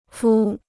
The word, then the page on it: 敷: fū